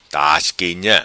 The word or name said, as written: Tasquinha